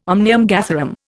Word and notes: omnium gatherum: Indian

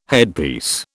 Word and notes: headpiece: British English

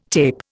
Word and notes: tape: Indian

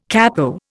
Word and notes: capo: Indian

